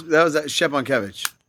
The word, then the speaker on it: Szczepankiewicz: That was Szczepankiewicz.